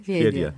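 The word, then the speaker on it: Fyedka: Fiedia. Fiedka yazılıyor ama evet, Fiedia okunuyor.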